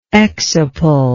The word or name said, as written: exciple